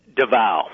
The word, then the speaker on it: Davao: Davao.